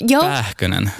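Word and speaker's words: Pääkkönen: Uttalar jag namnet rätt? – Pähkönen. – Pähkönen. Pähkönen. – Pähkönen. – Perfekt.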